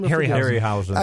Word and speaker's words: Harryhausen: Harryhausen.